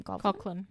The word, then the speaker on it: Coughlan: Coughlan.